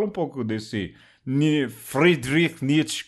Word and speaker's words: Friedrich Nietzsche: Esse texto maravilhoso que acabamos de ouvir é do filósofo, me corrija se eu pronunciar errado, oh Alexandre, Friedrich Nietzsche. É isso? – É, tá bom, esse daí é o sotaque do norte da Alemanha, né?